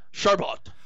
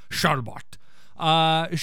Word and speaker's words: Chabert: So, okay, so Lacey Chabert, or Chabert, or Charbet, how do you say her name? – Charbot. – Charbot.